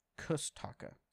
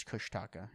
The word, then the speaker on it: Kushtaka: My sister Megan recently told me that for a long time she believed the Kushtaka, often pronounced Kustaka, Kustika, Kustika. I already did the Google Translate on this name a while ago, and it's Kushtaka when it says it so.